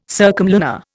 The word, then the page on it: circumlunar: Indian